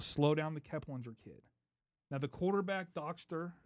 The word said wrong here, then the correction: Dockstader: or how do you say it? – Dockstader.